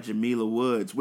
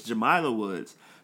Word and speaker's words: Jamila: she says that her name has a long I. So I was like, oh, I've been saying it wrong. It's Jamila Woods.